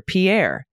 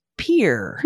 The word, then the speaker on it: Pierre: I would have pronounced it like this, like our then prime minister, Pierre. But in Nebraska, it's pronounced Pierre.